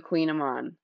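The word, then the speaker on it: Kouign-amann: Okay, if I'm being totally honest, I was actually thinking about pastries today, and I would love to master the Queen Amon. I don't know if you've had one of those before, and they are, the way it's spelled, it's like Kouign, but it's pronounced queen, and then Aman, Amann.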